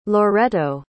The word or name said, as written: loretto